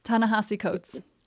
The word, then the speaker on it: Ta-Nehisi Coates: Ta-Nehisi Coates. – Ta-Nehisi Coates.